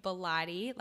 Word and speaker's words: Bilotti: So it's K-A-T-Y Bilotti, like Pilates. That's how I say to pronounce.